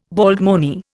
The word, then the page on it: baldmoney: Indian